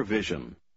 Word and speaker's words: vision: Однако в комбинации S-I-O-N она произносится как Жен. – Попробуйте прочитать номер 11. – He has a clear vision. Vision.